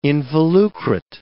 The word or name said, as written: involucrate